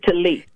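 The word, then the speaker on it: TELI: But more importantly, you have an organization that you are the visionary and founder of, and that's called Telly, and that's T-E-L-I. Am I saying it right? – I pronounce it Telly.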